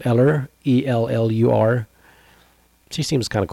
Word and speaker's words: Ellur: E-L-L-U-R. – Allure. Is it Allure? – No. – Eller? – It's like, I guess it's like Ella, but like with Eller, you know? – Okay.